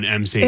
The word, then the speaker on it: emcee: As much fun as I would have to be your MC, you have to be your own MC. – It's all Mandy all the time. – Wait, I just realized. MC, even though it's spelled E-M-C-E-E, MC, Mandy Connell.